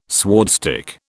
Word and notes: swordstick: British English

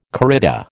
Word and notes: corrida: American English

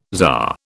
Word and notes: czar: British English

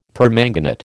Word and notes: permanganate: American English

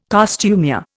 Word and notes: costumier: Indian

